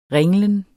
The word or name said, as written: ringlen